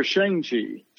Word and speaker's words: Shang-Chi: Is it Shang-Chi or Shang-Chi? – Shang-Chi. Shang-Chi is the correct pronunciation.